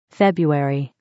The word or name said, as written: february